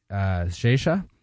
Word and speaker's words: Xexa: We've got Kemes Tu by Zesa. X-E-X-A, but of Portuguese pronunciation and origin.